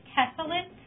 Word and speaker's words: Tessellate: So our vendor is Tessalit, formerly known as Storin.